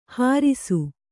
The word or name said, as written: ಹರುಸ